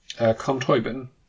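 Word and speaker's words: Colm Tóibín: Com Toibin.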